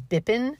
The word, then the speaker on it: Bippin: His name was Bippin, which is just a great name, not only because it rhymes with Pippin, which is one of my favorite musicals.